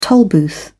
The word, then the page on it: tollbooth: British English